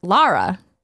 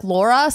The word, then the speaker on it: Lara: How do you say her name? And they said Lara. – Yeah. – So, but Lara. – If you look at the video game, though, characters in the game say her name differently. – What did they say? – Like Laura.